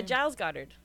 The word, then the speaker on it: Giles Goddard: And the pronunciation is Giles Goddard. Is that how you say his name? Yeah, Giles Goddard.